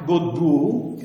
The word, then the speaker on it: Godbout: E qui l'antropologo, forse più i due diciamo, che più sono, hanno approfondito questa direzione, sono uno si chiama Jacques Godbout, che è un canadese, è scritto God come Dio in inglese, e Bolt, Godbout, Jacques Godbout,